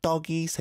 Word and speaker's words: 떡: 어른 말을 들으면 자다가도 떡이 생긴다. – 떡, it's got a 상 digit there, so be careful with the pronunciation. It has to be strong.